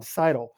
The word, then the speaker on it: Seidel: Okay, speaking of marathon, Molly Seidel is going to go to, I said it right, John, 2021, Seidel. – Nice Molly one. Seidel.